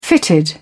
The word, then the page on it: fitted: British English